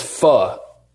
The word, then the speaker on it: pho: Dan, it's not faux Bichette, it's pho.